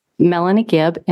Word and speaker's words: Melanie Gibb: Melanie Gibb, M-E-L-A-N-I-E-G-I-B-B.